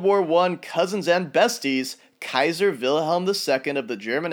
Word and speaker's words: Caesar: Keep in mind, the modern English way of saying Caesar is not the original Latin pronunciation. As difficult as it is for me to say, Caesar in proper Latin sounds more like Kaiser.